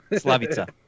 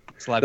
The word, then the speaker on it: Slavica: How do you say the first name again? – Slavitska Jovan. – Slavica. – Oh, okay. Slavica.